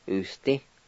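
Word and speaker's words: usdi: Number five, small, usti.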